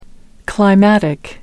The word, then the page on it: climatic: American English